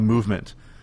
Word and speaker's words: MVMT: We'll have more with Josh in just one moment, but let me take a minute to tell you about Movement. Movement Watches, spelled MVMT but pronounced movement, was founded on the belief that style shouldn't break the bank.